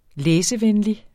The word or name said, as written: læsevenlig